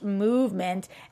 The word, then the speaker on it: MVMT: So it's spelled MVMT, but it's pronounced Movement.